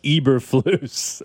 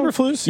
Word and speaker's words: Eberflus: Someone says, it's pronounced Eberflus. There's a few names that'll get you, you know? – Eberflus, yeah.